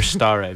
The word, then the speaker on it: stare: First of all, I'd just like to compliment you on your Latin pronunciation. – What did I say? – You don't hear stare very often.